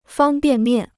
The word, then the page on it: 方便面: fāng biàn miàn